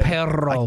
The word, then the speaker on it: perro: Does he roll his R's? – He does. It's so good. – Perro. – I cannot do it. – Perro. You can't roll your R? – No, not at all.